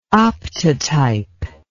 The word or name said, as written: optotype